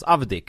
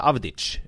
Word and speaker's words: Avdic: att hennes namn uttalas Avdic och inte Avdic. Alltså, för jag tänker, det är jugoslaviskt, eller för hon är jugoslaven, då är det ju ofta det där ljudet.